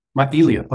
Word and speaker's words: syringomyelia: Is it syringomyelia? Syringomyelia? Myelia.